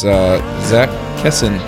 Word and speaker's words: Zach Kessin: That's Zach Kessin.